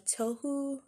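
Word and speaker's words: Touhu: So he's playing against one of the people in the representative's party a game of Tohu, is what I guess you would pronounce it as. It's T-O-U-H-U if you want to look it up and kind of see the game.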